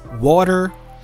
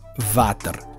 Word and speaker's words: water: So you have to work on that sound because the W has a W w sound. It's a glide. W, w. So you cannot confuse that sound with the labial dental v as in victory or as in van. So that's a different sound. So if you don't know how to pronounce that sound, you might make a mistake. So you have to learn that it could be water, it could be water, it could be water but never Vater.